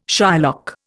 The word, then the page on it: Shylock: Indian